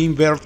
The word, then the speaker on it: Inwert: Y pues lo único que sabemos de Invert, que se escribe INWERT,